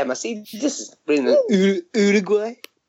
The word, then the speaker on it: Uruguay: Well, we say Uruguay with the accent on the last. – Oh, that's lovely. Say it again. – Uruguay.